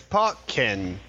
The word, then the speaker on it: Pokken: Pokken. – Why, I'm glad you asked, because the way that you would pronounce it is Pokken Tournament. – Pokken? – Like Tekken, but with Pokemon.